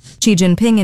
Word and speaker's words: Xi Jinping: Xi Jinping in Beijing for high-level talks on Thursday. – Hold on, she said the name right.